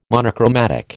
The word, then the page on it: monochromatic: American English